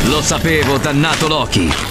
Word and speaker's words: Loki: No, non cambia, la pronuncia non cambia, è sempre Loki.